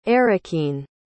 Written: errukine

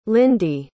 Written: lindi

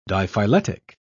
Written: diphyletic

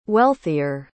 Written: wealthier

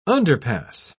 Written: underpass

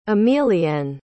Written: emilienne

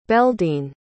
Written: beldene

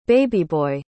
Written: babyboy

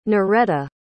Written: Noretta

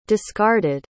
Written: discarded

